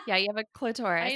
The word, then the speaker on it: clitoris: Yeah, but I was saying clitorious. I was adding an extra syllable.